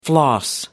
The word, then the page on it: floss: American English